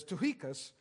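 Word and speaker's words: Tychicus: When I send Artemas or Tuchicus, that's how you pronounce that. I know it's not how it looks, but it's Tuchicus, to you, do your best to come to me at Nicopolis, for I have decided to spend the winter there.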